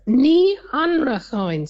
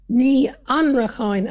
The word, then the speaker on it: Ní Anarchán: And the surname is Ní Anarchán, so it's four syllables. Ní Anarchán, and the stress is on the second. Yeah. Ní Anarchán.